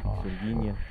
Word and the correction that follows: Pixinguinha: Es Pixinguinha, ahí lo dije bien, Pixinguinha, que no conozco quién es.